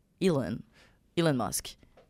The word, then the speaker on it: Elon Musk: J'ai vraiment prononcé ça tout croche. – Ben non. – Elon? – Bref. – On aurait dit qu'il s'appelait Hélène, là. C'est pas ça. Elon Musk.